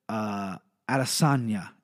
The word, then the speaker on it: Adesanya: Finally, I get to talk about my boy, Israel, Adesanya is how you pronounce his last name.